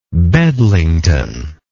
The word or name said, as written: Bedlington